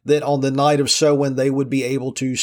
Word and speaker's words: Samhain: Now, if you look that word up, it's spelled S-A-M-H-A-N, but it's pronounced Samhain because languages are strange.